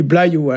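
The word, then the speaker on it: bloazioù: Alors ici, on prononce pas ce Z-là, hein, on dit blaïou.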